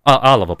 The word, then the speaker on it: Алава: за это Наварра и Алава при франкизме, да, мы всегда говорим про что франкизм это такой унифицирующий, но Алава Алова, прошу прощения, там ударение на первую букву.